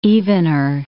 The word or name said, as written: evener